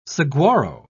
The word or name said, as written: saguaro